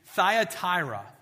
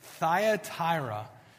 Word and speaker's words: Thyatira: I'm going to say it, and then you can try to repeat it. It's Thyatira. Thyatira.